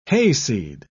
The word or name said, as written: hayseed